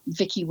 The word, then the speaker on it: Vicki: So if you look up Vicki Wusche, so Vicki is spelled V-I-C-K-I, and then the Wusche is usually the easy bit, W-U-S-C-H-E.